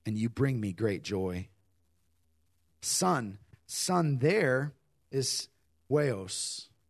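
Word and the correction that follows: huios (υἱός): No, it's huios. Sorry, I phonetically wrote it down. It's spelled with an H. H-U-I-O-S. Huios.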